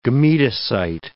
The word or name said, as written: gametocyte